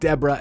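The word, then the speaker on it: DE3RA: I believe they're pronounced Deborah. It's D-E-3-R-A. The three's got to be a B, right? It's like an uppercase B.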